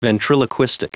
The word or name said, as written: ventriloquistic